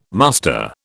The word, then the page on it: muster: British English